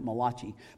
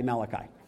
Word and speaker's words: Malachi: If you look at it in the Bible, though, you might think he's the Italian prophet, because you could pronounce it Malachi, but it's actually Malachi.